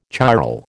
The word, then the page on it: chiral: American English